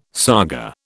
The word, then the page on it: saga: British English